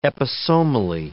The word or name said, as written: episomally